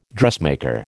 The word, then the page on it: dressmaker: American English